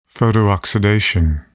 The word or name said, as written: photooxidation